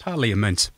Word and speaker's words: Parliament: Because that's how it's spelt, Parliament. – Yes. – All of the above, yes. – Parliament. – Parliament. – Parliament. It's like Wednesday.